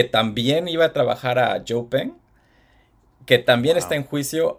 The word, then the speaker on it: Xpeng: Este, él lo había contratado una compañía china de, carros eléctricos que se llama Pen, que se escribe como Xpeng. y, pues ya se estaba yendo a China a trabajar con ellos con todos los documentos que se había robado.